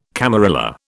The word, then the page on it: camarilla: British English